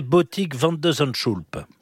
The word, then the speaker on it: Botik van de Zandschulp: Et donc là, en 8e, quel est le niveau de son adversaire, ce fameux néerlandais Botik van de Zandschulp? – Oh, mais vous prononcez très très bien.